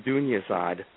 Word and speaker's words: Dunyazad: Dunyazad, I believe, and I'm sure it's pronounced, let me try that again, Dunyazad.